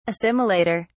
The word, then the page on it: assimilator: British English